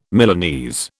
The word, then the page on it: Milanese: British English